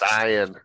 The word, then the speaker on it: Saiyan: Well, I said Saiyan yesterday when we were talking about, and Zach corrected me, it's Saiyan. At least in America, it's pronounced Saiyan.